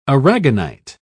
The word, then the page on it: aragonite: British English